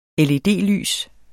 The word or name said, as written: LED-lys